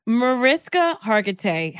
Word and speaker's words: Mariska Hargitay: because Marissa Harding Mariska Hargitay, how dare you? – Icon.